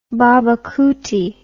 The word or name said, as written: babacoote